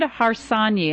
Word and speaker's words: Harsanyi: David Harsanyi. And David, please pronounce it correctly for us. – That's right. David Harsanyi. – David Harsanyi.